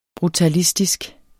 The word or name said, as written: brutalistisk